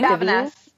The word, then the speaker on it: Davines: Divine, is that a brand? D-A-V-I-N-E. – Davine? – Davines. Okay, thank you. – It's Davines, yeah.